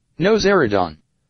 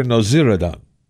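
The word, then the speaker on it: Nowzaradan: Noziridon. – Dr. Noziridon.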